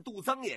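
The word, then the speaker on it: 杜曾: 这个层啊也念增，它是一个多音字，你念杜增也行啊，念杜层也行。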